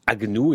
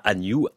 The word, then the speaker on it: Agnew: En tout cas, son nom Agnew se prononce Agnew et pas Agnew, A-G-N-E-W.